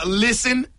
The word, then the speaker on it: listen: Listen is another one where the English words has a silent letter.